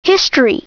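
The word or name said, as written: history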